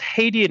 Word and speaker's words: Haiti: So it's Haiti in English, but people might know it also in Creole as IET.